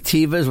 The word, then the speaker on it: Teva: What I call the Tevas, which apparently is pronounced Teva.